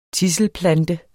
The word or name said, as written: tidselplante